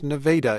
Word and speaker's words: Nevada: Nevada, spelled like Nevada, pronounced Nevada, is a farming community, and the results there did not match the rest of the state, though the spirit did.